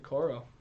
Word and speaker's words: Okoro: Isaac Okoro.